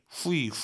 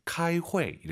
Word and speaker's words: 开会: 후이 후이 하지 마세요. UI 사이에 에 넣으셔야죠. 开会 이렇게 발음하시고요.